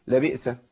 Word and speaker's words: لبئس: لبئس الهمزه ساكنه والهمزه الساكنه بيبقى فيه ثقه شويه في النطق اقول لبئس لبئس ما كانوا لبئس ما كانوا لبئس ما كانوا في فرق يبقى لابد من تسكين الايه اسكان الهمزه اكمل شيخ محمد ان شاء الله.